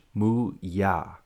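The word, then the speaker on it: Mooyah: I'm pretty sure it's spelled M-E-Y-O-O. – No, it's M-O-O-Y-A-H. Muya.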